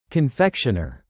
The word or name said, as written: confectioner